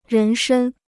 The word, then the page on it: 人身: rén shēn